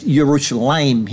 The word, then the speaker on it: Yerushalayim: It is pronounced Yerushalayim here instead of Yerushalem.